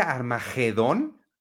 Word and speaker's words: Armagedón: espérame, eh, ah espérame, espérame, espérame, ¿Se pronuncia Armagedón? Sí, no tiene U. En español, no tiene U. Armagedón.